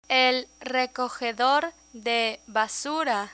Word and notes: recogedor de basura: Spanish

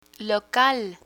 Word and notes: local: Spanish

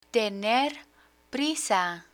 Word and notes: tener prisa: spanish

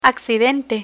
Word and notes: accidente: Spanish

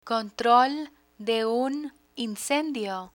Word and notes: control de un incendio: Spanish

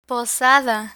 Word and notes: posada: Spanish

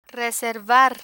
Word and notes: reservar: spanish

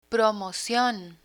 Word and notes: promocion: Spanish

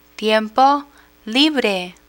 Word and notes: tiempo libre: spanish

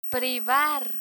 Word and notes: privar: Spanish

